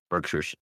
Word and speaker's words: Berkshire: Berkshires. – Okay. – I don't know. It depends. Like, I don't know. Some northern accent might be different, but I'm pretty sure it's like the English pronunciation, so Berkshire.